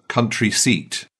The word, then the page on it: country seat: British English